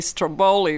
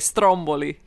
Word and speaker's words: Stromboli: And, you know, I'm laughing because that tells you how long I've been in the States, because we don't say Stromboli, we say Stromboli. That's how you pronounce right? – it, yeah.